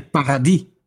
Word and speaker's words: paradis: They say paradis. They don't pronounce the S.